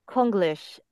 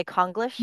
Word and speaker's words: Konglish: Konglish, which Korean Americans say Konglish. Very American pronunciation, but Koreans say Konglish.